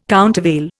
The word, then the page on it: countervail: Indian